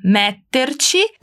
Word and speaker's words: metterci: Però non metterci tre ore per sceglierlo. – Dá aquela paradinha no me da palavra metterci para pronunciar bem a doppia consonante ti, ok?